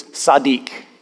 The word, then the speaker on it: tzaddik: The word just or righteous, just man or righteous man in Hebrew is tzaddik. Tzaddik is how it's pronounced.